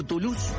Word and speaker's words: Toulouse: Toulouse se pronuncia Toulouse, y Marsella se pronuncia Marseille.